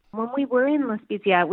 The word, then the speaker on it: La Spezia: La Spezia.